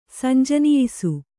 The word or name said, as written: ಸಂಜನಿಯಿಸು